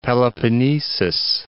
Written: Peloponnesus

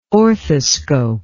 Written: orthoscope

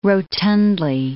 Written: rotundly

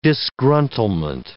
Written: disgruntlement

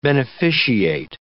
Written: beneficiate

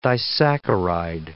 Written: disaccharide

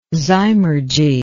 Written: zymurgy

